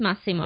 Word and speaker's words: Massimo: So it's Massimo.